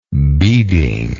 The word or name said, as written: beading